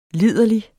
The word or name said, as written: liderlig